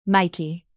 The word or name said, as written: nike